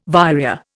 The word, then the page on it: vireo: Indian